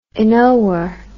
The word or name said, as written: inower